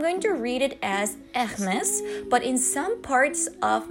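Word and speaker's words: Hermès: 那一般很多人读英语的时候，他习惯性读成 Hermes，嗯，Hermes 这种。但是我也研究了不少啊，也模仿了不少。它是因为法国的品牌，所以读的时候，尤其是你想描述这个奢侈品品牌的时候，读音应该要注意一下，怎么读呢？Hermes。 Hermes，哎，就是它会有一个呃和之间的区别，就是稍微啊、呃，就那种弱度弱度啊。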